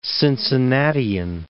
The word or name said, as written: Cincinnatian